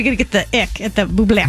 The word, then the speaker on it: Bublé: Isn't that how you say it? – Michael Bublé. – Michael Bublé. Because it's got the apostrophe on the end of it.